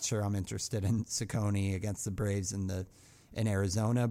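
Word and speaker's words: Cecconi: And the other one I always mispronounce, Slade, is it Ciccone? – Ciccone, yeah. – Ciccone, okay.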